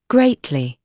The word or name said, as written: greatly